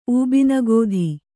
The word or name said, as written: ಊಬಿನಗೋಧಿ